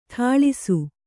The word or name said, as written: ಠಾಳಿಸು